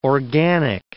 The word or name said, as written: organic